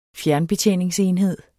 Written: fjernbetjeningsenhed

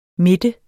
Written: midte

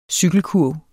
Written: CYKELKURVE

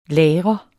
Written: lagre

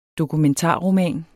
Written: dokumentarroman

